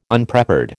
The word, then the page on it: Unprepared: American English